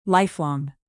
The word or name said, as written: lifelong